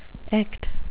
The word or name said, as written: echt